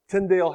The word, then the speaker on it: Tyndale: And by the way, Tyndale House Publishers pronounces it Tyndale, and Teams says it should be pronounced to rhyme with spindle.